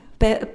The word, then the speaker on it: Pärt: Pärt, oui.